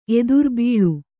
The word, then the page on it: ಎದುರ್ಬೀಳು: Kannada